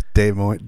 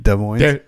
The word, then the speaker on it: Des Moines: Des Moines. – Des Moines? – Des Moines. – I'm so good at this. – I'm so good at reading. – I'm so good at making reading. Des Moines.